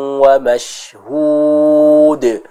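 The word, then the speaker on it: wa mashhud (مَشْهُودٍ): Di sana harus kita jelaskan pengucapan huruf shinnya. Mashhud ya. Tadi yang Ibu Yani baca shinnya belum sempurna.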